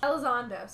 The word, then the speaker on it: Elizondo: Hector Alonso, like, is... Elizondo. Sorry, I was dropping a syllable.